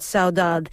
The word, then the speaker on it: saudade: And you pronounce it in the Brazilian way, by the way. – Saudade. – Yes, which is great.